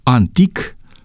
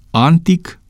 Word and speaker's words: antic: De asemenea, există în limba română unele neologisme mai vechi, cu două variante de pronunțare, ambele acceptate de normă. Așa sunt cuvintele antic, profesor, trafic. Așa se pronunță aceste cuvinte după etimonul francez, dar norma a acceptat și pronunția antic, profesor, trafic, după obișnuința românului de a accentua cuvintele pe penultima silabă.